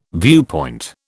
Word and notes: viewpoint: British English

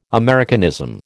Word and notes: Americanism: American English

